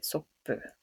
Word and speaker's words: soppu: It's soppu probably comes from the Dutch word for soup, and somehow the Japanese probably pronounced it soppu.